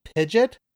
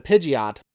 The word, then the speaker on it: Pidgeot: and they actually changed the pronunciation to Pidgeot.